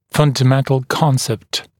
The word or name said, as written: fundamental concept